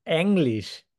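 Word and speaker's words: Englisch: How do you pronounce that? – English. – It's English. – English. – English. You can think about English. – Like the word energy. The English word energy.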